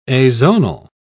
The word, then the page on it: azonal: British English